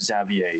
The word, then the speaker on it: Xavier: Javier Dolan. Is that how you pronounce his name? – Uh, so the thing is, Zav Xavier Xavier Dolan, but close, very close. – So yeah, it's my explanation. to a Z sound.